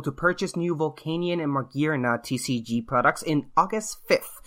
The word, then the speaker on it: Magearna: It's Magearna.